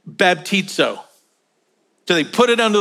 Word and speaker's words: baptizo: And the Greek word is pronounced baptizo, sounds just like it.